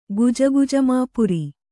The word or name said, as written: ಗುಜಗುಜಮಾಪುರಿ